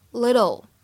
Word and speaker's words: little: 我们在美式发音当中呢，里面的 double t 会有一个闪音的处理，所以在美式发音当中啊，你会感觉这个单词当中的 t 是介乎于 t 跟的之间的感觉，会读成 little little。